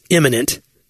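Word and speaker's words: immanent: Like, and it's imminent. I-M-M. It's not, it's spelled like imminent, but pronounced differently. It's got an A.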